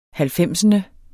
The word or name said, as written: halvfemsende